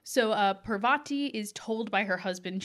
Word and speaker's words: Parvati: Which I wrote down so that no one yelled at us about the pronunciation. – I know. We read Harry Potter as children and formed our pronunciations then, people. So we are learning. – Sorry. We're doing our best. So it's Parvati.